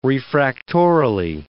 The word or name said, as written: refractorily